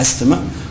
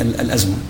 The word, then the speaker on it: الأزمة: هي تكتب هكذا بحروفها فيها حرف التاء، فالبعض ينطقها أستمة وجرى العرف على هذا الأساس. جميل. لكن هي أساسا هي الأزمة. – الأزمة. – نعم. وهي مأخوذة من اللغة العربية الأزمة.